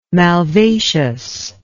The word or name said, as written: malvaceous